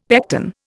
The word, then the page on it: pecten: Indian